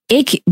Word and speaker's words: 애교: 애교.